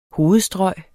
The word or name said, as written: hovedstrøg